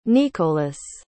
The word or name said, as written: nikolas